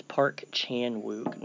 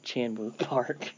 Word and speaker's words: Park Chan-wook: It's Park Chan-wook, not Chan-wook Park.